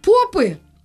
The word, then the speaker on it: Попы: э, а, один интеллектуал подписан просто, э, называется инстаграм-аккаунт «Попы». Ударение я правильно поставила.